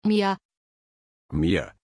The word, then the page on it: Mya: German